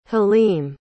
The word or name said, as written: haleem